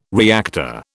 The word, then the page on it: reactor: British English